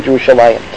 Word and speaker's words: Yerushalayim: In fact, even Jerusalem, actually the English is close to the consonants of the Hebrew, Yerushalem, as opposed to Yerushalayim, although we pronounce it Yerushalayim.